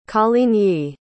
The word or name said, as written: Coligny